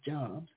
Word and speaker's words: Job: His name is actually spelled like it would say Job, like Steve Jobs. But actually, it's pronounced, we pronounce it Job.